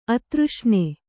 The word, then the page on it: ಆದರ್ಶನ: kannada; ādarśana